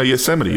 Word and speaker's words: Yosemite: No, Yosemite.